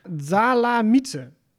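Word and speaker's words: Dzalamidze: Maar Natale, nou, dat moet je eigenlijk uitspreken. Zalamitze. Prima uitgesproken.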